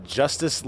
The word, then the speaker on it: Justis: Yeah, it's Justice Logan Morrison, and you spell it J-U-S-T-I-S.